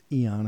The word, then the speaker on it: Aeon: Of course, it's pronounced Eon according to the Cambridge official, but it's Dark Aeon.